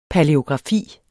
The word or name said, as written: palæografi